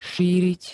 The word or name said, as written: šíriť